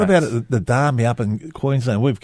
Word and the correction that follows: Adani: The Darnie, how do you say it? – Adani.